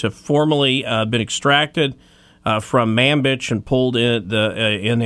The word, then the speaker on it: Manbij: I'm just really sad I don't get to report on skirmishes in the town of Manbij anymore. So. – Manbij.